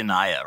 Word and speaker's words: Naya: Is it Naya or Naya? – It's actually Naya, Rob.